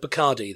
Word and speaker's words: Bacardi: Bacardi. Because it rhymes. The white rum of choice. – It rhymes with party, of course.